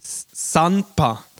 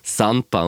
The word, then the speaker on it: Sanpa: Das heißt Sanpa mit N. Nicht mit M. Sanpa. Sanpa.